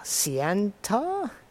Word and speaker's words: Ciantar: C I A N T A R Ciantar?